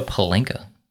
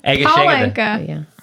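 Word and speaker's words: pálinka: I know Palenka. – No, you said it wrong. Palenka.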